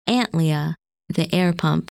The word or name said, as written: Antlia